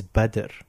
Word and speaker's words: Badr: My name is Badr. – Oh, okay. – Badr. – Badr. – Badr. So how do you pronounce it? How do you spell it? B, like bad, but like badder.